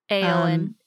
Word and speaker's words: Eowyn: So it is a Mayfair Pens Eoin. Am I pronouncing that correctly? – Eoin. – Eoin. Aowen model.